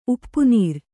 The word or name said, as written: ಉಪ್ಪುನೀರ್